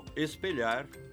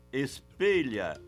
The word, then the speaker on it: espelha: Timbre fechado, como no hino nacional. E o futuro espelha essa grandeza. Apesar disso, parece haver uma tendência a pronunciar, no linguajar coloquial, com timbre aberto. Fecha, lacrimeja, espelha.